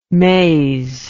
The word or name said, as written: mays